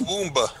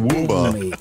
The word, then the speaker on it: Womb: Pronounced Womba. Isn't it Womba? – The Womba Mates. – Rhymes with Roomba.